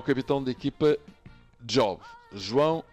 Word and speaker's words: Job: J-O-B. Job.